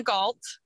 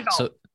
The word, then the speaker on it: Gault: Okay, my Instagram is Gina Galt. It's my first and last name. My last name is spelled G-A-U-L-T is like tiger.